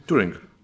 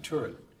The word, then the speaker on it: Turing: Um, but still, I think, uh, I, I, do believe in what um, Turin, I don't know if you know who Turin is, he was the founder of artificial intelligence. I think I have his name wrong. Tur- Turing. – Yeah, Turing.